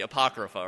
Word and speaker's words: Apocrypha: I think I probably mispronounced it at that time. It was like the Apocrypha. It's actually the Apocrypha, right? In the South, we like to put the emphasis on the first syllable, right?